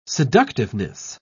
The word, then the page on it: seductiveness: British English